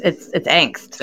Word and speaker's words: angst: it's angst. Angst, like that word has a pronunciation. – Yeah, but I've also heard people say ank instead of onk, and I'm like, you're all very confusing.